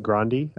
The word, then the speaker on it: Grande: or as I learned this week, it's pronounced Grande, actually, and Scarlett Johansson.